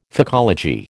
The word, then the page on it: phycology: American English